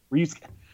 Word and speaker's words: Ryusuke: Ryusuke. – Oh, these U's, man. I swear these U's. I thought I nailed it for once. – You know, like Ken and Ryu from Street Fighter? It's Ryusuke.